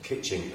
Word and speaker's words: Kitching: Kitching.